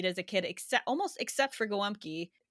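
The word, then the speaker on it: gołąbki: And yes, I pronounce these things like a Chicagoan with a Polish accent because this is how it was pronounced. – That's who you are. – There was pierogi and also gwumki, which I don't know if you've had that.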